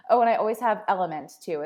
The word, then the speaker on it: LMNT: Yeah, so it's called Element, and it's spelled L-M-N-T.